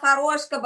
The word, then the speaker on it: Horoshko: Well, my full name is Tatiana Horoshko, but call me Taty.